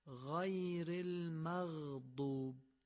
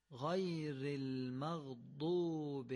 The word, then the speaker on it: المغضوب: پس در این ذکر شریف مواظبت کنیم که غین رو نرم و قابل کشش تلفظ کنیم. اگر نرم باشه قابل کششه. غیر المغضوب، مغ یعنی قابل کششه.